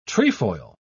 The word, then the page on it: trefoil: British English